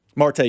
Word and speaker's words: Marte: Is that how you pronounce his name? – Marte, yeah.